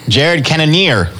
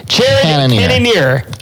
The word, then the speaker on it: Jared Cannonier: Like, Canaris or Canada. – Jared Cannonier. Cannonier. – Yes. Jared Cannonier.